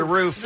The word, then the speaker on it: roof: There are two common ways to pronounce this four-letter word. – Roof. – While people born and raised in the West tend to pronounce the word as if it rhymes with hoof, in other words, roof, those from the East see it as rhyming with poof, as in roof.